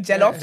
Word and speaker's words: jollof: It's not J-E. I swear when people say jollof. – Jollof. – It's jollof. J-O-L-L-O-F.